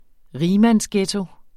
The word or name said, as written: rigmandsghetto